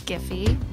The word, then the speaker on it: Giphy: I'm like, is it Jiffy or Giphy? – It's Giphy. – Okay. – We say hard correct. – G for hard G's.